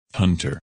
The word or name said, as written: Hunter